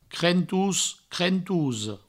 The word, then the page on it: crentos: crentosa; crentous; crentouso